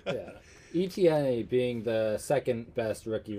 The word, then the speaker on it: Etienne: And then Travis Etienne, or I don't even think I pronounced his Etienne. – Name. Etienne.